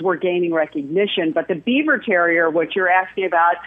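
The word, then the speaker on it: Biewer: And it was and it's spelled differently, but it's pronounced Beaver Terrier.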